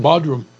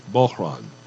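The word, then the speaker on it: bodhrán: Bodrum. – Yep. Yeah. no, no, no, no. You have to pronounce it correctly. It's Bohran. – Okay. You swallow the D.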